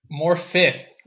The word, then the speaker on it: Morfydd: Morfydd. I had to look up how you pronounce her name. It's Morfydd, like as in ITH, even though it's spelled Morfydd.